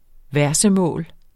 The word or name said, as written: versemål